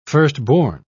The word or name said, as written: firstborn